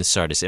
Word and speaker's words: Sardis: And to the angel of the church in Sardis write, the words of him who has the seven spirits of God and the seven stars. So here we begin. To the angel of the church in Sardis.